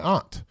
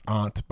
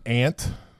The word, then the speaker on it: aunt: I'm taking along my mom, my wife, Carlisi, and my aunt. And it's pronounced aunt, by the way, not aunt. It's aunt. It's A-U, August.